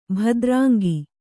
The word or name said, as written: ಭದ್ರಾಂಗಿ